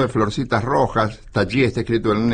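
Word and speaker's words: Tajy: Taji. Se escribe Tají con J y griega, pero me han dicho que se pronuncia Taji.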